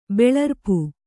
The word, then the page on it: ಬೆಳರ್ಪು: Kannada